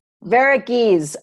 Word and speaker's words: Varughese: Today, I have a colleague from Calgary, Tina Varughese. Am I saying that properly? I know you have it explicitly written here. – You were very close. Varughese. – Varughese. Okay. Yeah, I missed an A there.